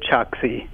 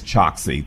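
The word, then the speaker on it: Chokshi: It's Dr. Dave Choksi. – It is Choksi.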